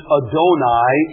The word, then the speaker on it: Adonai: Adonai, we pronounce it Adonai in English.